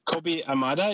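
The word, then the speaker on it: Kobi Yamada: Kobe Yamada, is that – or? – Yes, it's spelled differently.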